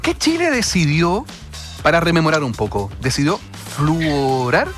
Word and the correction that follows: fluorurar: a ver, lo dije bien, fluorurar el agua?